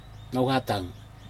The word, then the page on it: nohatog: Aneityum